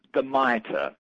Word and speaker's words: Mitre: How do you spell that? What is it? – The Mitre. M-I-T-R-E.